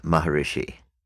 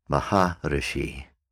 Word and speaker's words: Maharishi: In the West, we say Maharishi, and that will do. That's quite all right to say it that way, great seer, Maharishi. In India, it's pronounced a little differently, Maharishi, Maharishi, Maharishi. When they put it all together with a bit of speed, Maharishi turns into Maharishi.